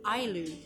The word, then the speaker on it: Ayllu: Ayllu.